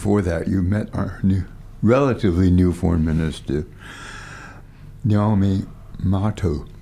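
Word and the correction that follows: Nanaia Mahuta: Nanai Mahuta. – Nanai Mahuta.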